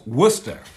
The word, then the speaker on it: Worcester: That's Worcester.